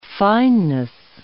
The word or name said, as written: fineness